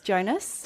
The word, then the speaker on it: Jonas: Are they pronounced Jonas? – Jonas.